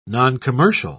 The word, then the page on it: noncommercial: British English